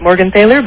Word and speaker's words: Morgenthaler: You pronounce it the way Arnold does. We pronounce it Morgenthaler, but you're obviously talking like the big...